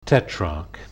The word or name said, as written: tetrarch